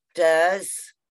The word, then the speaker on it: Deuxes: Deuce, Deuces, D-E-U-X-E-S. Apparently I say it like Deuces, Deuces, but looking at it, it says duh.